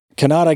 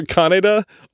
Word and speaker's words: Kaneda: It's not Kanata. It's either Kaneda or Kaneda, but it's spelled K-A-N-E-D-A.